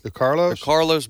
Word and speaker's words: DeCarlos: DeClaros Brown, how do you pronounce his name? – DeCarlos Brown, 14 times he's been to court for arrest.